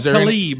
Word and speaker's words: Tlaib: Talab? – Rashida Tlaib. – Tlaib. Is there some silent letters here? – Tlaib.